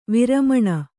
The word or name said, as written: ವರಮಾನ